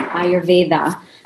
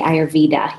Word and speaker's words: Ayurveda: Yes, I pronounce it Ayurveda, Ayurveda. But lots of people say Ayurveda here.